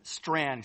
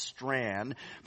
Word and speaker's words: Strachan: Strand, excuse me. I know it looks like Strachan, but it's pronounced Strand.